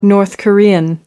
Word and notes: north korean: American English

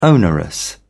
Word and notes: onerous: British English

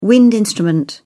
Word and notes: wind instrument: British English

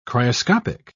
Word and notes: cryoscopic: British English

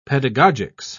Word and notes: pedagogics: British English

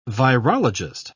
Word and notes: virologist: British English

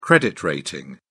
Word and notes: credit rating: British English

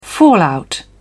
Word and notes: fallout: British English

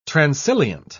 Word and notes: transilient: British English

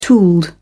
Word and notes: tooled: British English

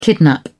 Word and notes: kidnap: British English

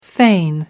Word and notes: fain: American English

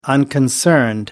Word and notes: unconcerned: American English

